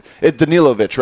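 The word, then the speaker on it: Danilović: Danilovic, right? Am I saying this right? Am I getting the name right? Yes.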